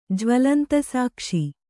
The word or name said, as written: ಜ್ವಲಂತಸಾಕ್ಷಿ